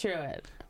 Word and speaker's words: Truett: Killer Bees here for, what's it say his name was? Truett? Truett Beasley? – Yes. Like, yes, that's, it is Truett.